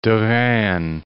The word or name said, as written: Derain